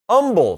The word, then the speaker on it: Humble: They're watching us in Akron, Ohio, Cave Spring, Georgia, Humble, Texas. The H is silent.